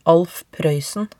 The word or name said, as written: Alf Proeysen